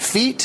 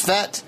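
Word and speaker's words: Fete: I was pronouncing it feet. Someone told me it's pronounced Fet.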